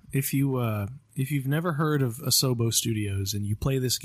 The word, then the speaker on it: Asobo: So shout out to Asobo.